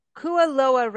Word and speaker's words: Kualoa: So Kualoa Ranch, am I pronouncing that correctly? – Yes.